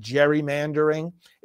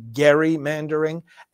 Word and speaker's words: gerrymandering: You know, when lines are drawn to accommodate a partisan interest, this is called, and it's often mispronounced, many people pronounce it gerrymandering. It's actually gerrymandering, and that is named after a politician.